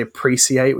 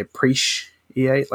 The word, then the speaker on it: appreciate: So we don't say appreciate, we say appreciate, like it's sh.